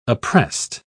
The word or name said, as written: appressed